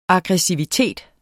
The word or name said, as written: AGGRESSIVITET